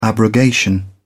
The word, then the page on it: abrogation: British English